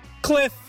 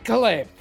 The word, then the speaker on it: Kliph: You got the last name right, the first name wrong. – Okay, say your first name. – Kliph. – Kliph. – Nesteroff. – I said Kliph. – I heard Kliss. – I heard Kliss too.